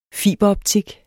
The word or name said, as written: fiberoptik